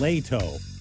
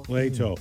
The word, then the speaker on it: Leto: I think she says Leto is what the. – Jared Leto. – Leto. Mm. He was climbing the Empire State Building yesterday, this guy. Jared Leto, the actor, great actor.